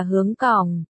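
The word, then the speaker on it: hướng cỏng (香港): Tên gọi Hồng Kông, xuất phát từ tiếng Quảng Đông đọc là hướng cỏng,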